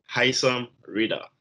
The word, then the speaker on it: Haisam Rida: It's Haisam Rida, correct? That's how you say your name. – Haisam Rida.